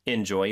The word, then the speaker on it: enjoy: Enjoy your flight. – 빈칸에는 Enjoy, capital E-N-J-O-Y. J-O-Y. – 네, 발음이 중요하죠. Enjoy가 아니고 enjoy. 이 음절의 강세가 있습니다.